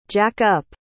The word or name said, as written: jack-up